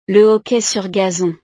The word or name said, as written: le hockey sur gazon